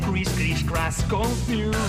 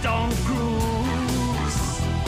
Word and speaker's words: Tom Cruise: Decís Chris Confuse. Es Tom Cruise.